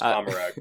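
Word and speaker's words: Commorragh: In Comorra? – Comorra? Comorra? – It's pronounced Comorag, by the way. – Comorag? – Yeah. – Okay. Then I totally mispronounced that.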